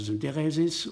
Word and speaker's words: Syntheresis: Das ist ja dann entweder mit T oder D geschrieben, griechisch, also Betonung auf der dritten Silbe, drittletzten, also Syntheresis, und lateinisch heißt dann Syntheresis oder Syntheresis.